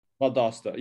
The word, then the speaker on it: Valdosta: That's how you say it? – Valdosta. Yeah. – Valdosta. Valdosta. Okay, yeah.